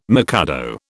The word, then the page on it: mikado: British English